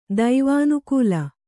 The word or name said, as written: ದೈವಾನುಕೂಲ